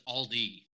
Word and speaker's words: Aldi: Yeah, Aldi's is own. You say Aldi's, but it's Aldi. Is owned by Trader Joe's. – It is Aldi.